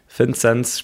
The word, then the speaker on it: Vinzenz: Sollen wir dann lieber Vinzenz sagen und nicht Vinzenz? Oder wie hättest du es gerne? – Ähm, ich glaube, das, das, das Thema hat sich äh, erledigt, eigentlich nach meinen ersten äh, Ausflügen äh, von Oberstdorf äh, in Richtung, äh, die, die, äh, in Richtung ganz Deutschland. Ich glaube, Vinzenz spricht man mich natürlich richtig aus, so wie meine Eltern mich getauft haben.